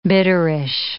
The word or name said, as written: bitterish